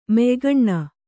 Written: ಮೆಯ್ಗಣ್ಣ

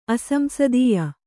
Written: ಅಸಂಸದೀಯ

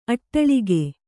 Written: ಅಟ್ಟಾಲಕ

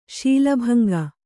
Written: ಶೀಲಭಂಗ

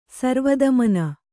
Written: ಸರ್ವದಮನ

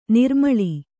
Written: ನಿರುಮ್ಮಳ

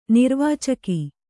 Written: ನಿರ್ವಾಚಕ

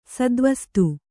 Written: ಸದ್ವಸ್ತು

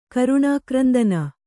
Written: ಕರುಣಾಕ್ರಂದನ

